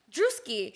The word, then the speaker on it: Druski: Who's Juski? – Druski. – Druski. Oh, Druski. – How do you say it? – Druski. – Druski? – I say Druski.